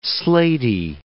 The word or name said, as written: slatey